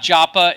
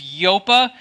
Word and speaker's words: Joppa: It was not Joppa, it was Yopa.